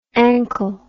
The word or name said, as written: anchyl